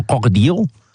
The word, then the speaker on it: Crocodillo: Is it Senor Crocodillo? – Just a little tip for players at home. A double L is pronounced as a Y in Spanish.